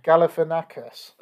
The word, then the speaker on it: Galifianakis: Pronounce it for me. – Galifianakis. – That's what I said. – No, you did not.